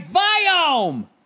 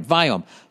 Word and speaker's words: Viome: All right, Viome. Hey, you broke your cherry. You finally said it right. All right, Viome.